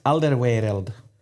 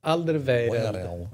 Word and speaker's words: Alderweireld: Va, kan du säga Tobes efternamn igen? – Alderweireld. – Alderweireld. – Ja, Alderweireld. – Bra uttalat. Alderweireld. – Ja, den är, den är, där får man jobba med tunga.